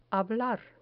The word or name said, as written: hablar